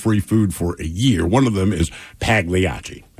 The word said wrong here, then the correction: Pagliacci: So one person says, hey, man, the G is silent in Pagliacci. – I know that. – Another one says it's Pagliacci, not Pagliacci.